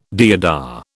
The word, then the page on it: deodar: British English